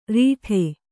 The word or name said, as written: ರೀಠೆ